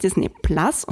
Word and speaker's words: Disney Plus: Ja, es heißt Disney Plus und nicht Plus, man schreibt es mit einem schönen Pluszeichen hinter dem Namen, weil es auf Englisch ausgesprochen einfach auch noch viel cooler ist.